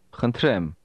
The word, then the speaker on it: Խնդրեմ: Խնդրեմ. Ռեմ. Ռեմ. Խնդ. Խնդ. Խնդրեմ. Խնդրեմ. – This word begins with a sound that is similar to the English H, but it's pronounced with a lot of breath.